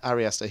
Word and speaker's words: Ari Aster: as Ari Ast- Aster. Am I saying the right? Uh, Ari Aster. He, yeah, he just, I think that's right. yeah.